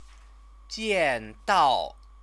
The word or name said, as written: jiandao